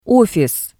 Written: ofis

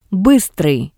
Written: Bistrii

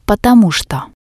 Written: potomu tsto

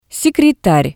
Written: sekretar

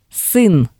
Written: sin

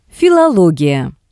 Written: filologiya

